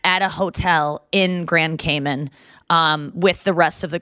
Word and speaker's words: Cayman: That's how I learned how to say it. So it's not Cayman, it's Cayman.